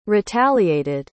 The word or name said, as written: retaliated